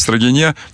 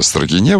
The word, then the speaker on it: Строгино: Рамси Болтон пишет мне неоднократно, что ударение в Строгине надо ставить на последний слог, поскольку именно с теми, значит, слова, названия населенных пунктов, которые заканчиваются на гласную и ударную, не меняются.